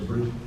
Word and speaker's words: Brisbane: Well, Tuesday we'll be going to Brisbane.